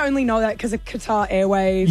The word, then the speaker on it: Qatar: Is it guitar? Like a guitar? – Qatar, isn't it? – Correct. – Oh, yeah. Qatar.